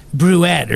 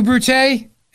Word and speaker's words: Brute: It was like Et tu, Bruette or something. I forget now, but no, it's Et tu, Brute, and you, Brutus.